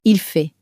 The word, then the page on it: il fait: French